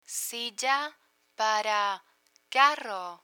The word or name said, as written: silla para carro